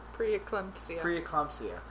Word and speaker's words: preeclampsia: Preeclampsia.